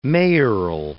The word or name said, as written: mayoral